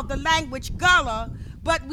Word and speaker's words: Gullah: The language today, it's called gullah. It's spelled G-U-L-L-A-H.